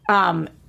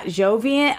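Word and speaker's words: Joivan: I think his name is actually pronounced Javian, not Jovian. I haven't figured this out yet. Wade, I've heard every single broadcaster say it differently. – I thought it was Jovian Wade, but maybe I'm wrong. – I don't think it's a J-O sound. I think it's actually a J-A sound.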